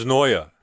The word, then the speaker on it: Noyer: It's spelled Neuer, N-O-Y-E-R, probably pronounced. – Neuer. – Yeah, probably pronounced like that.